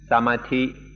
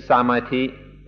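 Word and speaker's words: samadhi: Another word related to this subject that we'd like you to understand is samati bhavana. Samati, or you heard it pronounced yesterday, bhavana. One is a Western pronunciation, one a Thai pronunciation.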